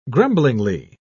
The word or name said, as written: grumblingly